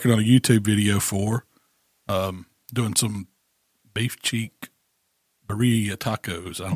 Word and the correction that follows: birria: You say birria. – it. Yeah, yeah, birria.